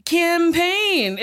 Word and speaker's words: campaign: Campaign is a big word. Campaign. It's two syllables.